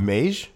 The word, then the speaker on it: Meij: How do you pronounce his last name? M-E-I-J. Harold. – Mage.